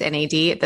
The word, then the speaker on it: NAD: I've even heard it, the way you say it is NAD, the plus is silent.